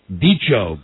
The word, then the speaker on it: bicho: For instance, the word, I hope I'm pronouncing this correctly, bicho, B-I-C-H-O. – It sounds right.